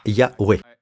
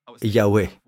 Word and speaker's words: Yahweh: Regardez le nom de Dieu, Jéhovah. D'ailleurs, les Français ont essayé de le translittérer en disant Jéhovah, d'accord Ce n'est pas Jéhovah. Personne ne sait vraiment comment cela s'écrit, ou plutôt comment cela se prononce. Ça s'écrit comme ça, d'accord Yahweh. Personnellement, je dirais Yahweh.